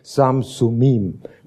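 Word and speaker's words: Zamzummim: Some of your translations say Zamzumim. Mim is just a Hebrew way of pronouncing the plural.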